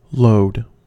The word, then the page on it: load: American English